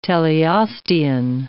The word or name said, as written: teleostean